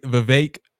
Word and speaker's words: Vivek: Former primary candidate Vivek rhymes with cake, Ramaswamy.